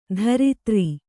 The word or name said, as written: ಧರಿತ್ರಿ